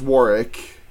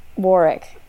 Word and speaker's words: Warwick: It is pronounced Warwick. – Warwick.